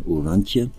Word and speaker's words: Oranje: Und dieser Orantje, Orantje wird er ausgesprochen oder Orange River ist genau der Grenzfluss zwischen Namibia und Südafrika.